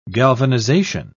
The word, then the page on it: galvanization: British English